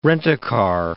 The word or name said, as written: rent-a-car